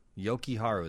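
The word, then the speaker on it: Jokiharju: It's pronounced a J like a Y. Talking about the new defenseman, the Finnish defenseman. – Yoki Haru, that's what you say? – Yoki Haru.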